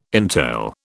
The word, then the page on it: entail: British English